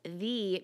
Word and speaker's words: Thee: So I've heard of Megan Thee Stallion, and I say it like Thee because it's T-H-E-E.